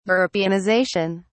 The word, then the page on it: europeanization: British English